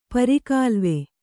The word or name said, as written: ಪರಿಗಾಲುವೆ